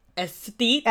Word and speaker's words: aesthete: Yeah, I know, but that was not that word. It's like not athlete, and it's not aesthetic. It's not aesthetic. – It's aesthete.